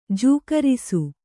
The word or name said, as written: ಜೋಗರಿಸು